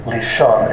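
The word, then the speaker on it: Lishon (לשון): How do you say that in Hebrew? – Lishon, L-I-S-H-O-N. L-I-S-H-O-N, Lishon.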